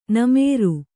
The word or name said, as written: ನಮ್ರ